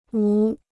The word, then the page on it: 无: wú